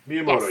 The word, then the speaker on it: Miyamoto: Miyamoto, yes.